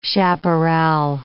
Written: chaparral

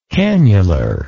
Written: canular

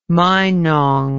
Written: meinong

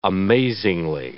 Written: amazingly

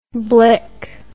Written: blik

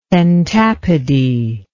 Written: pentapody

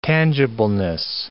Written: tangibleness

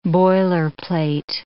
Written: boilerplate

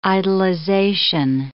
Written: idolization

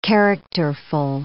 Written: characterful

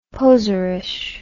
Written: poserish

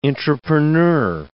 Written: intrapreneur

